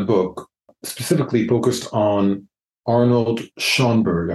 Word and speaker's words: Schoenberg: I'm not sure if I'm pronouncing that correctly. And you describe him as an apocalyptic prophet. What was it about Schoenberg that was so prophetic? – Yes, and you got the name right on the nose there. Arnold Schoenberg,